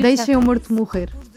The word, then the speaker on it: Deixa-me Morto Morrer: There is a track called Deixa-me Morto Morrer. Am I pronouncing that? Am I butchering that pronunciation? – It's in Portuguese, it's very hard to pronounce Portuguese, but it's almost perfect.